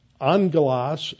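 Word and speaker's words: Angelos (ἄγγελος): Angelos, even though it's got a double G in there in Greek, a double G is pronounced like an NG.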